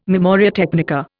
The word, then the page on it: memoria technica: Indian